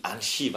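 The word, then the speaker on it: Anzhi: fordi han spillede i Anchi, var det vel? – Altså, ja, præcis. Præcis, godt udtalt.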